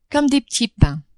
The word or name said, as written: comme des petits pains